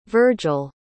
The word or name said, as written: vergil